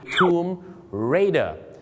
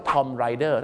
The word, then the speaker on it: Tomb Raider: Tomb Raider แต่บางคนนะครับไปออกเสียงว่า Tom Rider